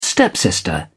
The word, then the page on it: stepsister: British English